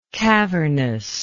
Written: cavernously

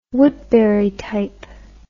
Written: woodburytype

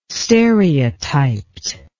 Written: stereotyped

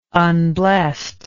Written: unblessed

